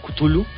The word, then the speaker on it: Cthulhu: Pues, según decía H.P. Lovecraft, la pronunciación más parecida era algo así como... Oh. Pero obviamente nuestro cerebro lo interpretaba como Cthulhu. Y pues esa es la versión más aceptada.